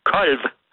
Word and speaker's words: kolv: Kolv, det er et substantiv, og kolve, det er et verbum. Og, og det udtales på jysk kolv. Kolv?